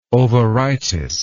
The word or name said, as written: ovaritis